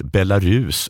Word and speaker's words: Belarus: Den svenska uttalet har långt u-ljud och betoning på sista stavelsen. Belarus.